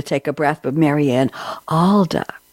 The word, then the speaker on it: Aalda: Mariann Aalda, and am I pronouncing it right? Aalda, A-A-L-D-A, is that correct? – Yes, you are.